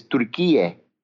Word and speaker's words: Türkiye: Yes, the Turkish way is Türkiye and the Greek way Türkiye.